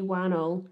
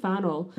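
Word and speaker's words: Whannell: Lee Wannell. – Yeah. – I keep his last name begins with a WH, so I keep trying to pronounce it like it's Teremori and being right. Like Lee Fannell.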